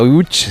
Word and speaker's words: Łódź: Aunque se pronuncia Łódź, se escribe Lodz, por si alguien lo busca. – Lodz, casi no tiene nada que ver la pronunciación.